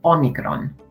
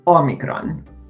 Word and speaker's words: Omicron: It's pronounced Omicron. Omicron.